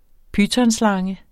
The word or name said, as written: pytonslange